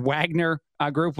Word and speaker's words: Wagner: One other example I'll keep throwing out there today, the Wagner Group, or Wagner Group was the way I've been saying it, just because that's the way it looks and is spelled here in the United States.